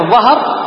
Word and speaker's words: الظهر: هنا الظهر ينطق ويكتب بالضاء. وقع منه في القرآن 14 موضع، أولها كتاب الله وراء ظهورهم، كتاب الله وراء ظهورهم.